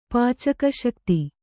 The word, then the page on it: ಪಾಚಕಶಕ್ತಿ: kannada